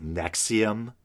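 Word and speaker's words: NXIVM: And the name looks like a mess of Roman numerals, but I believe it's supposed to be pronounced Nexium or something like that. And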